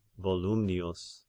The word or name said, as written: Volumnius